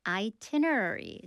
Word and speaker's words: itinerary: And then the chatbot, or rather the AI agent, can figure out your itinerary. 스펠링 해보겠습니다. I-T-I-N-E-R-A-R-Y. Itinerary. 그 첫 번째 I는 그대로 발음해 주세요.